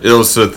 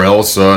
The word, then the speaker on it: Ilsa: Ilsa, thank you. Is somebody else a problem? Because I keep wanting to call her Elsa, and I want to let that damn joke go.